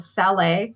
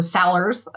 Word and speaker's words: Salers: There's another one called Saler, S-A-L-E-R-S. So Sellers, I guess, if you pronounced it English-wise.